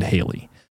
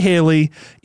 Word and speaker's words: Halley: And I'm going to say Halley, even though I kept listening to videos that said Halley and it's spelled with two L's and that's probably true, but nobody says Halley's Comet in America. So I'm not saying it.